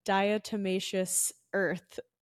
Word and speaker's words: diatomaceous earth: Shifting gears, I want to talk about silica and diatomaceous earth.